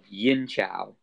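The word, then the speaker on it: Yin Qiao: A Chinese formula, which is a classical formula, is called Yin Chao. And there are various versions of this. It's spelled Y-I-N, and the second word, C-I-A-O, Yin Chao.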